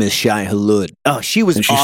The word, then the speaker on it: Shai-Hulud: And it seems like the Fremen worship the sandworm, the Shai-Hulud. I don't know how to say it. – Shai-Hulud, yeah. – Shai-Hulud.